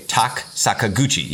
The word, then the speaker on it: Tak Sakaguchi: Tak Sakaguchi,